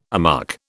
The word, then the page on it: amuck: British English